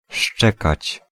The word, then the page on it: abbaiare: [ab.ba.ˈja.re]